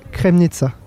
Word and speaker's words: Kremnica: Il faut pas que je l'écorche, j'étais à Kremnica. Ça se dit comme ça ? – Oui, en Slovaquie centrale.